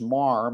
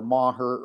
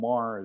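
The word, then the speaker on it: Meagher: It's pronounced Marr. Many people would say Meager or Maher or some other thing, but plain old Marr is good.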